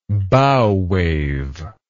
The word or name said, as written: bow wave